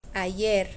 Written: ayer

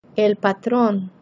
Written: el patron

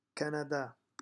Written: Canada

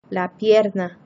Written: la pierna

